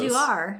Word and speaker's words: Ulduar: Live from Eldar. – Sound it out. – U-L-D. Uldu, uh, You're close. Ulduar. Yeah, Ulduar. Ulduar. Yes, live from Ulduar.